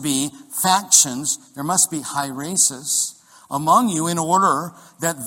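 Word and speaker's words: hieresis (αἵρεσις): And the Greek word is pronounced hieresis or hieresis, okay?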